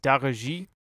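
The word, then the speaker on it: Daara J: There's a Senegalese rap group called, I believe it is pronounced Daraji, spelled D-A-A-R-A and then a J.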